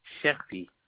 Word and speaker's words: Cherfi: Cherfi.